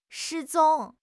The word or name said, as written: 失踪